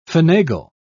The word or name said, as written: finagle